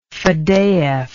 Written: fadeyev

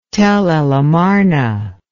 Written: Tell el Amarna

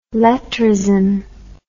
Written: lettrism

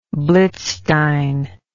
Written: blitzstein

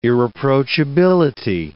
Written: irreproachability

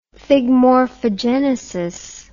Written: thigmorphogenesis

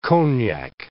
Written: cognac

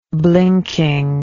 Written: blinkingly

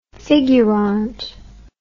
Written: figurant

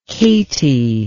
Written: katie

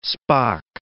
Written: Spock

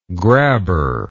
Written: grabber

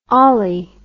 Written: olly

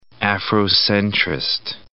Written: Afrocentrist

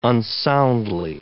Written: unsoundly

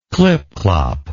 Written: clipclop

